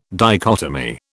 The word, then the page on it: dichotomy: British English